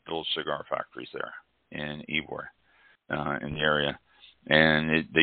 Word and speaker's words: Ybor: And for those of you out there in the listening world, Ybor City, and it's spelled Y-B-O-R, is pronounced Ybor.